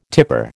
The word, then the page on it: tipper: American English